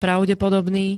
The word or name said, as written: pravdepodobný